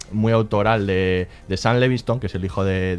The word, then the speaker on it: Levinson: Es Levinson, lo digo ya bien, y a partir de ahora lo diré mal en todas las demás.